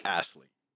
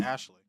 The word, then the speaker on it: Astley: Never Gonna Give You Up by Rick Astley. Yes. – Sometimes I say his name like Ashley.